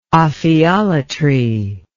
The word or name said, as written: ophiolatry